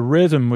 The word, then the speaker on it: rhythm: But the rhythm would get you tonight is R-H-Y-T-H-M. Is two H's, one is silent, and the other is using a diphthong, T-H. It sounds like it should have a U.